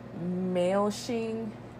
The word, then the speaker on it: Maoshing: and Achieve Optimum Wellness by Dr. Maoshing Ni or Nai.